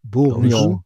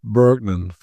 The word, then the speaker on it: Bourgnon: Mathis Bourgnon, wie spricht man ihn? – Stimmt. – Bourgnon. Aber Bourgnon ist völlig in Ordnung. – Mhm. Bergnon wird der Amerikaner. – Nein, sagen. – Bergnon kannst du abhaken. Wird nichts.